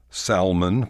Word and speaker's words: salmon: Salmon for salmon. The L in salmon is silent. So don't ask for smoked salmon. Smoked salmon will do. However, if you order in Spanish, the L is okay.